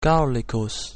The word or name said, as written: Gallicus